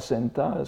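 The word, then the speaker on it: Senta (センター): en japonais standard les Bunka Senta, c'est la, quatrième ligne de, ce que je donne là, n'est-ce pas Senta étant la prononciation japonaise de center,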